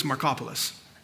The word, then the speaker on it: Markopoulos: His name is Harry Markopoulos. It's funny because his last name literally is spelled like Marco Polo's, but it's Markopoulos.